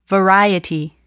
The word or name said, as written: variety